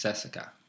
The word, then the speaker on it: CSKA: When I was in Bulgaria, I would now, how I say it, CSK Sofia, but now that I've been there and I know, it's CSKA. That's how you pronounce that in their language, in their culture.